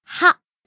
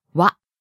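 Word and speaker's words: wa (は): The particle wa is spelled ha in hiragana, but it's pronounced as wa. – Good point. This can be a little confusing. This particle is pronounced wa and not ha.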